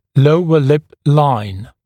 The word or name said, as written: lower lip line